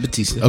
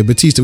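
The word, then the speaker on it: Bautista: Uh, so we talked about David uh, Batista. How do you say his name? Yeah, Batista. Okay, Batista.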